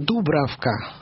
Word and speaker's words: Дубравка: Дубравка, тут скорее двойное надо ударение, чешская принцесса.